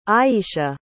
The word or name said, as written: Iyeshia